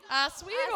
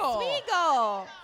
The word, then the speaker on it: Oswego: Oswego. – Oswego.